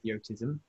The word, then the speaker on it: Yotism: Have I said that right? Yotism. – Yeah.